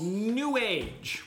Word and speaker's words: New Age: It's called New Age, which I immediately disparaged already by calling it. It's pronounced Newage. It rhymes with sewage.